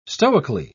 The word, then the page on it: stoically: British English